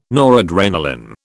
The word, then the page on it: noradrenalin: British English